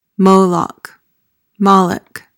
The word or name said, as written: moloch